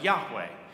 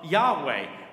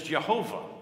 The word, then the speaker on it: Yahweh: So in the Middle Ages, they were trying to fill in the vowels and trying to come up with a pronunciation, and they actually pronounced it as Jehovah. And so the mispronunciation of Jehovah and Yahweh mean the same thing.